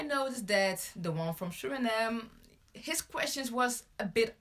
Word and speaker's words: Suriname: From Suriname. – Suriname, yeah.